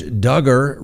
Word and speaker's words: Duggar: Josh Duggar rhymes with hugger.